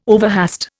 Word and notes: overhaste: Indian

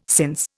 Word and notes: since: Indian

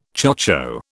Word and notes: chocho: British English

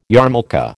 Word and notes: yarmulke: American English

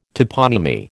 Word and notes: toponymy: American English

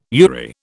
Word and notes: URI: British English

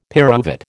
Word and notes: pyruvate: American English

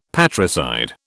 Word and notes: patricide: British English

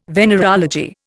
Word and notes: venereology: Indian

